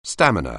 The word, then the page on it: stamina: anglais